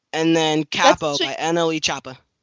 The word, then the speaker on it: Choppa: Chopper. You said Chopper. It's Chopper.